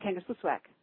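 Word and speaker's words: Kangerlussuaq: from Baltimore, the airport that you fly to, the town that you fly to, which is the same as the airport, it's pronounced Kangaskwisswak, but there's no L, there's no S in the middle of it.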